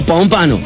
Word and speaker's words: Pompano: ¿Cómo se pronuncia? Pompano, Pompano Beach, Florida. Saludos. Míralo vos. Míralo vos. Desde Pompano Beach. Pompano, se dice Pompano.